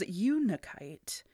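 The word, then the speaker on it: Unakite: I had to watch many a YouTube video to make sure I was saying it right. But it is Unikite.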